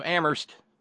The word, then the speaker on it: Amherst: From the wilds of Amherst, where only the H is silent, and some of us still heat with wood.